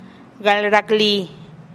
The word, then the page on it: gal raklii: TdVZ